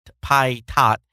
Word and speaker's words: Pai: All right, Netflix today patiently explained to FCC Commissioner Patat that Patat that CDNs and I, if I it mispronounced his name. I uh, okay, it's P A I T H A T. It almost sounds like Pod Thai, Pod Thai, Pai Tot.